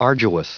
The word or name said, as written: arduous